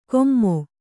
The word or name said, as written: ಕೊಮ್ಮೊ